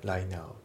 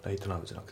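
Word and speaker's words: right now: ただいま当社の年末在庫一掃セールの期間中、新しいテントや寝袋、その他の商品を大変お得にお買い求めになれます、うん、で発音ですけど、ライトの T がね抜けますね。ライナーだね。ライトナウじゃなくてライナーで、次のデュアリングアワーのところがリンキングでデュアリンガーだね。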